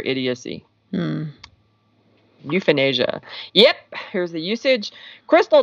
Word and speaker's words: euphenasia: All right, the second word of nine in a row is euphenasia. Okay, so this is not euthanasia.